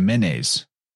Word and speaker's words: Manes: You know, they called it the Manes or the Menes. And that's what Hermes was the guide to the Manes. And interesting, because that's spelled the same as manes, like back to that horse symbolism.